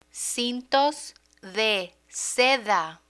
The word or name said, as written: cintos de seda